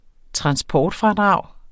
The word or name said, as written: transportfradrag